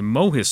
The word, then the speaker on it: Mohist: Dick Teresi writes about a school of philosophy in China called the Mohist School, if I pronounced it correctly.